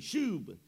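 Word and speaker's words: shub (שוב): The Hebrew word for restoreth is S-H-O-O-B. I don't know that I could pronounce that, but it's S-H-O-O-B, or that's the English translation of the Hebrew word, S-H-O-O-B, shub.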